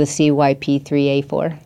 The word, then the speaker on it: CYP3A4: Oh, because somebody else has already come in and stolen all of the CYP3A4. – Yeah, you said it correctly.